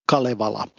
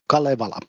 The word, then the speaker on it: Kalevala: Oh yeah, it actually, the pronunciation goes Kalevala in Finnish calm way, Kalevala.